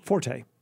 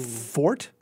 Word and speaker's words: forte: That's my F-O-R-T-E. – Forte. No. Fort? No. – Fort. – Really? – Fort. Music buffs might pronounce it forte because mm-hmm.